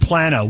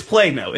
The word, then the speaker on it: Plano: Hey, we got Texans pronouncing it Plano and Plano. Which is it? – It's Plano.